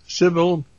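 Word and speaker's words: Sibel: And I just thought I'd talk a little bit about this interview we did this afternoon with Sybil, is the way she likes her name pronounced, Edmonds.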